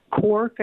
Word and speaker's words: Quark: In your travels to Europe, did you ever come across a real young cheese called Quark, or it's spelled Q-U-A-R-K, Quark, I guess, if you wanted to English it.